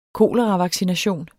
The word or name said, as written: koleravaccination